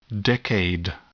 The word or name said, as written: decade